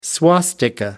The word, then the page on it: swastika: American English